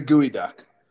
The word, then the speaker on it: geoduck: It's a gooey duck. – A gooey. Well, that boy, that is, does that ever track? – Yeah. It's spelled geoduck, but it's a gooey duck.